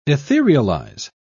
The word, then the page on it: etherealize: British English